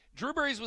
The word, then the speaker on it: Drew Brees: It rhymes with clue trees, okay? Drew Brees was accurate on 83.2% of his pass attempts.